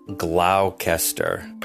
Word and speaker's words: Gloucester: And yes, it's spelled Gloucester, but it's pronounced Gloucester.